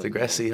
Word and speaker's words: Degrassi: So can you explain a little bit about, I'm not sure I can pronounce the name of the show correctly. Degrassi? – Yeah, Degrassi. – That's it.